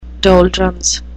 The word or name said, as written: doldrums